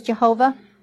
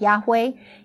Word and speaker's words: Yahweh: Whenever we look in the Bible and see L-O-R-D, all capital letters, it's Yahweh, which is the personal covenant name of God. Now, sometimes it's referred to as Jehovah. I have chosen to go with the correct pronunciation of Yahweh.